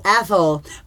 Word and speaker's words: Athol: Today our five answers come from James Maxwell from Athol, Massachusetts. Here with my five answers. That is not pronounced asshole, but it's close, he says. So I guess it's Athol. – Athol.